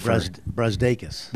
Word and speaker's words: Brazdeikis: Brazdeikis. – There we go. – Iggy Brazdeikis.